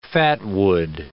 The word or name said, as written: fatwood